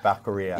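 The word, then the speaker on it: Becerra: Baccaria. – Yeah, it rhymes with diarrhea.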